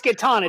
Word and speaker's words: Catan: There's, I know you asked people whether or not you pronounce it Catan or I can't even say it the other way. – That's right. It's Catan.